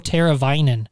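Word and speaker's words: Teräväinen: And it was actually a former Blackhawk, his line, that really killed the Hawks, and that's Teuvo Teravainen.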